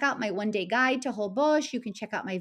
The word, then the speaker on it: Holbox: Holbox, spelled Holbox but pronounced Holbox, is incredibly beautiful.